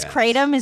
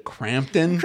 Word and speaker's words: Kratom: Is Kratom? Is that how it's pronounced? – That is how it's pronounced. Kratom. – It's because the last time in this show, you called it Crampton.